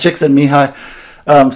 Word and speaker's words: Csikszentmihalyi: This guy who wrote the sort of most famous book about this called Flow, his name is, it's a word that starts with C, that C is unpronounceable, but you say it, Csikszentmihalyi.